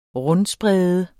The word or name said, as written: rundsprede